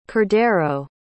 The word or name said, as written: Cordero